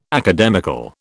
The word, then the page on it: academical: British English